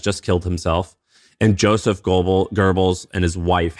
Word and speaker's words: Goebbels: So basically Joseph Goebbels, is it Goebbels? – Goebbels is how you pronounce it. Goebbels.